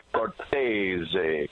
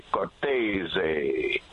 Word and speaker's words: Cortese: Well, like you said, old school Cortez, but I went straight to the horse's mouth one day, and I said to my dad, how do we pronounce our last name? He said, Cortese, Cortese.